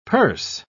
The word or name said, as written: perse